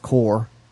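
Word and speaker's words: corps: It's pronounced core. – Oh, did I say corpse? – Yes. Because corpse is the thing that lays in the morgue. – It says corpse. – Where? – Right there. – Yeah, that's core. That's pronounced core.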